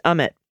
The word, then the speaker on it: Amit: Amit Mehta is pronounced Amit. Equal emphasis on both syllables. Mehta, slight emphasis on the meh.